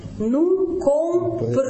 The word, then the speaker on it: comprenons: Буква «Д» пропадает. Второй слог оказывается открытым. Буква «Н» оказывается между двух гласных. И во втором слоге носового нет. «Ну компреном». Вот. Буква у нас это читается же как «Э», носового нет, слог открытый, губы вперед бантиком.